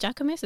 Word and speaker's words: Jacquemus: He did a runway show for, is it Jacquemus? Is that how you say that? – Yeah, yeah, that's how you say it.